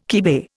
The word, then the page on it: kibe: Indian